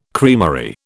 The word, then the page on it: creamery: British English